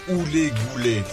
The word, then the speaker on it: Hully Gully: Il faut prononcer Hully Gully si l'on ne veut pas être pris pour un croulant.